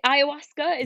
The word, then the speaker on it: ayahuasca: And was explaining in great detail about is it ayahuasca? Is that the right way to pronounce it? – I think it is. Yes. That's pretty much it.